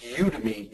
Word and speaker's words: Udemy: I have a class, by the way, it's pronounced Udemy, U-D-E-M-Y.